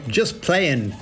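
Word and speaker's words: Just Playin': JT Players just playing. Oh, actually, I pronounced that wrong. There's no G in playing. In that song, anyway. Just playing.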